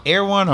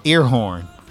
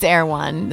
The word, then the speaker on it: Erewhon: Wait, is it called Erewhon or Earhorn? – It's Erewhon. That's how you say it. Erewhon.